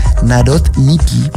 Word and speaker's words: Narodniki: Esa era su pronunciación, la trato de hacer en un idioma más o menos soviético, pero es Narodniki.